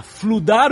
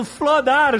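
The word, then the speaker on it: flood: A gente acha que é certo falar fludar os comentários. Você deveria estar falando flodar os comentários.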